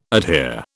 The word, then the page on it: adhere: British English